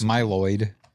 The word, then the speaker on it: Mylod: Is that how you say his name? Mylod? – Yes. – Mylod. Mylod.